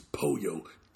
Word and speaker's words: pollo: Even though it's got two L's I never got the pronunciation of that. It's pollo chicken. yeah.